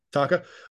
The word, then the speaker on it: Taake: And then we have, I don't know how you say his name, Hoest of Taka, T-A-A-K-E. – Yeah, I don't know if it's Hurst or... – Hurst. – Yeah. – How do you say his band name? I've never known how to say that. – Taka.